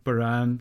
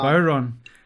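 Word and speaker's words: bodhrán: how do you pronounce it in Ireland? – Bowron. – I think we say Baran in here, but that sounds like a bastardization of the, pronunciation.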